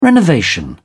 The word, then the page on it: renovation: British English